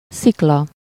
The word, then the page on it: rocher: France; /ʁo.ʃe/; /ʁɔ.ʃe/